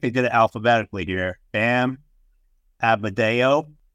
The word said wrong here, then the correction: Adebayo: Adebayo. You guys thought I didn't know how to pronounce that.